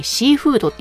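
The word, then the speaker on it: 海風土: まさに今田さんが醸してらっしゃるお酒、シーフード、海に風に土と書いてシーフードって読むんですけども、このシーフードのような方だなと思います。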